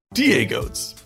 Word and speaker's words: DieGOATS: DieGOATS? That's correct. I'm saying that right? – Well, we were both named DieGOATS, greatest of all time. DieGOATS. Got it? – Okay. DieGOATS, basketball, kicks, and culture.